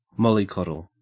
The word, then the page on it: mollycoddle: Australian English